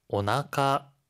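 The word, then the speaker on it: 오나카 (お腹): 이때 나카는요, 우리가 배복이라고 하는 한자를 써요. 그런데 읽을 때는 나카라고 읽어요. 여러분, 나카는요, 우리가 가운데를 나타내잖아요. 그래서 신체 중에서 가장 가운데에 있다라는 의미를 담아서 배복을 나카라고 읽는데요. 이 경우에는 앞에 오가 꼭 붙어야 돼요. 그래서 오나카 배라고 합니다.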